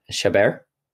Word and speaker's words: Chabert: Chabert? Oh, like Care Bear.